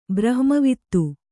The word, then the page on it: ಬ್ರಹ್ಮವಿದ: brahma vida